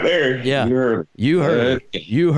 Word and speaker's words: Euharlee: Euharlee, uh, that's the name of the place. Euharlee. – You, Hurley. You Hurley. Now I know I'm saying it right because I heard them say it.